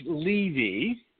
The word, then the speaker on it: Levy: Oh, it, by the way, just so you know, it's Levy. – Levy. And, my bad. – Yeah. That's it's right. Levy. You know, there's three different ways to pronounce that name, so it's really fine.